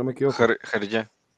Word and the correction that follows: Hergé: Hergé, gracias, Hergé. Gracias por, la, por el francés ahí, Rob.